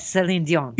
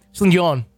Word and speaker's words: Celine Dion: You have to say it like that, Celine Dion. – Yeah, exactly. Just like, Celine Dion.